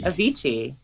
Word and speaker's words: Avicii: Avicii.